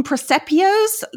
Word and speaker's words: presepio: Presepios? These little nativity scenes? Is that how you say it? – Yeah. – Yep. Presepio. – Presepi in plural.